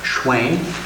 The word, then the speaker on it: Schwinn: My name is Mark Schwinn, and yes, that does rhyme with Mark Twain.